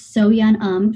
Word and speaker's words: Soyeon Um: It's Soyeon Um.